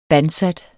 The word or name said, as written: bandsat